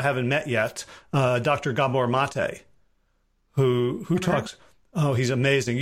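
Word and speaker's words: Maté: His last name is spelled M-A-T-E, like checkmate.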